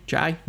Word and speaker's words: Jai: Um, uh, Jai Courtney is that how you say his name? Jai. Mm-hmm.